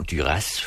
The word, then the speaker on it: Duras: Marguerite Duras plejer vi at sige, men hun udtaler selv sit navn Duras, fordi hun kommer fra et sted i Frankrig, hvor man altså gør det.